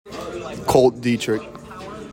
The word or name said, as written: KOLT DIETERICH